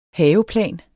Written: haveplan

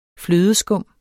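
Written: flødeskum

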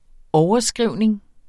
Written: overskrivning

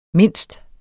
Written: mindst

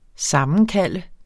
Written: sammenkalde

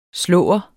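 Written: SLÅERNES

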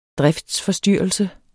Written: driftsforstyrrelse

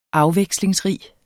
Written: afvekslingsrig

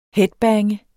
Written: headbange

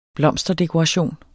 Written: blomsterdekoration

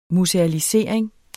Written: musealisering

